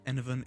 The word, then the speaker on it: Enevun: Enovan is spelled E-N-E-V-U-N.